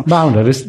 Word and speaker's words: Boundaries: l'evoluzione Opening Boundaries. No, l'avrei pronunciato malissimo. – Boundaries, giusto, hai detto bene.